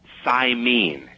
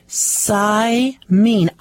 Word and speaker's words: Saimin: but the correct pronunciation is Saimin, and it's a very popular soup over there. – Saimin.